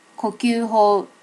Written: kokyuho

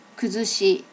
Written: kuzushi

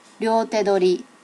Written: ryote dori

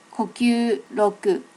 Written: kokyuryoku

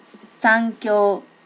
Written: sankyo

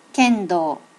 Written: kendo

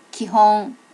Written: kihon

